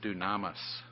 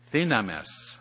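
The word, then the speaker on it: thinamis (δύναμις): Well, the word power comes from Strong's 1411, and it's from, now I would pronounce it dunamis, but Sister Isabel has told me it's thinamis.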